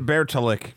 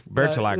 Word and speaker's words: Bertilak: I just uh, call her Lady Ber- Bertalik. – Bertilak.